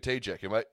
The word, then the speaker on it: Tajak: and I have the privilege of welcoming our guests with Landon and I tonight, Rebecca Tajak.